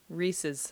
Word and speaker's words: Reese's: Reese's. Did I say it right? – Reese's. Reese's. Like pieces.